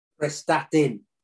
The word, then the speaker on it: Prestatyn: And you went to go play back in the Welsh Premier with, is it Prestatyn Town? Is that how you say it? – Prestatyn.